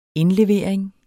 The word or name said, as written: indlevering